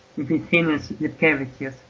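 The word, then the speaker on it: Vytenis Lipkevičius: Ein weiterer Forward ist auf alle Fälle Vitenis Gipkivicius. Oder wie die Aussprache richtig geht. Ja, Vitenis Lipkevicius, genau.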